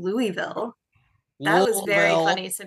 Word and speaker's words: Louisville: You just have to act like there's people in your mouth. – Louisville. – L-O-L-V-I-L-L-E. You got to slur through it.